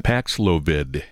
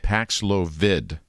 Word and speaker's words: Paxlovid: We're told it is a mild case at this point, and he's taking that Paxlovid. Is that how you say it? – Paxlovid. – Paxlovid, which we heard so much about toward the end of the COVID crisis.